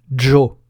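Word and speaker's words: ĝo: Le G chapeau se dit jo, comme le DJ français du mot adjoint ou du prénom Jamel.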